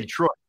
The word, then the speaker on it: Detroit: It's Detroit. Yeah. Detroit. – We'll get one of these days. – Just think Troy. Think Troy, not D.